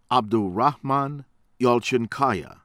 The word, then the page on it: Abdurrahman Yalcinkaya: American English; from Turkey